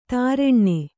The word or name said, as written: ತಾರೆಣ್ಣೆ